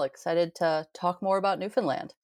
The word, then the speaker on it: Newfoundland: And it's Newfoundland.